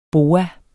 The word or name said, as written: BOAER